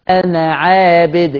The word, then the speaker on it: أنا: ألف أنا بالذات في القرآن لا تنطق وصلا، أدي قاعدة قلته، لا تنطق، كلامي واضح، لا تنطق ألف أنا وصلا. ولا أنا عابد، أنا عابد، كأني نون مفتوحة بس، أنا عابد ولا أنا عابد ما عبدتم.